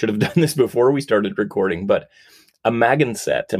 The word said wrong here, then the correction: Amagansett: am I saying that right? – Absolutely not. – Okay. – Amagansett. – Amagansett, okay. – Amagansett. It's exactly how it is spelled, and it's not how you would think. It's a Native American word from the Montaukett Nation.